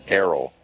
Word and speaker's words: Aro: Now she's a synth pop artist who goes by the name of Arrow. – Interesting. – And they spell it A-R-O, but it's pronounced Arrow.